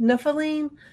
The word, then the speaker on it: Nephilim: The Nephilim, is that how you say it? Nephilim? Mm-hmm.